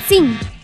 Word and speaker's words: tzin: Abajo en Náhuatl se dice tzin. Y bueno, se escribe T-Z-I-N, tzin.